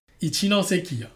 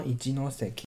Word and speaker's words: 一ノ関: 一ノ関啊。 – 啊，一ノ関，然后大船渡线，然后再到气线沼。